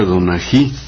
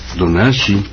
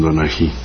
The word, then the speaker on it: Donají: Fue la presencia de la banda Princesa Donají, aunque en zapoteco se escribe Donashi, Donashi, en Castilla le dicen Donají.